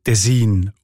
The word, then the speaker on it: Tessin: Und in diesem Dialekt sieht man Tessin oder Tessin, also auch auf der zweiten Silbe betont. Und im Italienischen ist es ja auch Ticino und nicht Ticino. Jetzt hat aber die deutsche Sprache die Tendenz, die Wörter auf der ersten Silbe zu betonen.